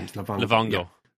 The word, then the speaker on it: Livongo: Isn't that the right pronunciation? – Yeah, Livongo.